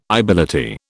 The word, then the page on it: ibility: British English